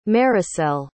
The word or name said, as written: maricel